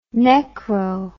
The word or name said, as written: necro